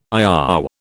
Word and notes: IOW: British English

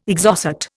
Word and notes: Exocet: Indian

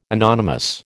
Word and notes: anonymous: American English

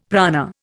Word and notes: prana: Indian

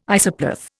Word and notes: isopleth: Indian

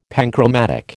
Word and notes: panchromatic: American English

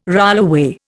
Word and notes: rollaway: Indian

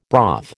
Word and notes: broth: American English

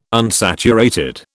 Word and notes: unsaturated: British English

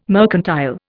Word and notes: mercantile: Indian

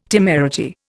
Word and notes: temerity: Indian

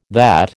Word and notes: that: American English

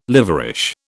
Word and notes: liverish: British English

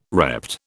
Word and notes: repped: British English

